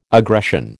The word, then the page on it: aggression: American English